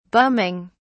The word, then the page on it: bumming: British English